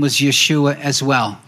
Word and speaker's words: Yeshua: And it wasn't pronounced Joshua because that's the way we pronounce it. – Also wurde nicht Joshua ausgesprochen, weil so sprechen nur wir ihn aus. – It was pronounced Yeshua.